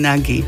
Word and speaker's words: Nagy: Vieme, že sa to vyslovuje Naď a vedia to aj poslucháči, ktorí však majú kolegu so špecifickou požiadavkou. – Napísali mi poslucháči, že čudné na tom je, že ten ich kolega chce, aby ho vyslovovali Nagy. A ako ho v tom prípade majú skloňovať?